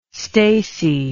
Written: stacey

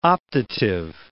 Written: optative

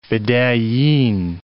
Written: fedayeen

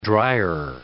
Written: dryer

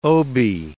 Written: Obie

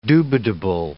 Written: dubitable

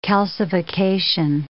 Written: calcification

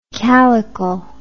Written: calycle

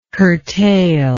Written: curtailment

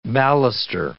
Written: baluster

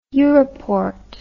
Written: europort

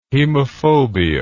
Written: hemophobia

